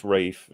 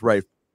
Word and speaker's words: Ralph: Wait, Ray Fiennes or Ralph Fiennes? – It's pronounced Rafe, I think. – Pronounced Rafe.